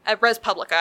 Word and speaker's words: Res Publica: In fact, a lot of my personal library that I have brought to book club have been recommendations from Res Republica. Res Publica, I keep mispronouncing it.